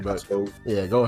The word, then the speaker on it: Costco: Apparently it's pronounced, you're supposed to pronounce the T, so Costco.